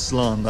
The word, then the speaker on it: slán: Or I could say goodbye, which is slán.